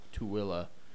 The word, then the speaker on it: Tooele: No, it's spelled so weird for how to pronounce. – Yeah, it's Tooele. Utahn.